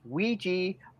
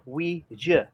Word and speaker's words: Ouija: It's pronounced either Ouija or Ouija.